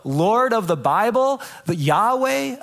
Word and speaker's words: Yahweh: It looks like this. It's pronounced Yahweh.